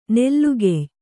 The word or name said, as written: ನಲ್ಗೆಯ್